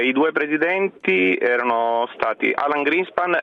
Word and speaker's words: Alan Greenspan: Alan Greenspan e Ben Bernanke.